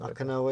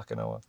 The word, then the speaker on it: Okinawa: Am I right by pronouncing it? – Okinawa, right? – Yeah. – Okinawa. – Yeah.